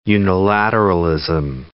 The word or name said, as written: unilateralism